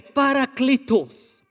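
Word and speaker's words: parakletos (παράκλητος): so I checked with him first. He said it's parakletos.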